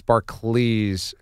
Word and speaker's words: Barclays: Right, it's Barclays, almost like Charles Barkley.